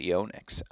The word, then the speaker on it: Yonex: We've got you pronouncing Yonex the correct way. Man, we've really done our job over here, I guess. – Well, I just hear Dave in my head constantly saying it's actually Yonex.